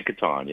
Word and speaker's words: Catan: Oh, Catan, it's pronounced Catan? – We say Catan, yeah.